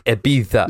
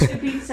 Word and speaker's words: Ibiza: This guy on this video just said the Z in Spanish is pronounced with your tongue between your teeth like a th. Ibiza. – Lynn says she can't come in because she's not wearing trousers. – Ibiza, and that's so listeners, that's behind the paywall.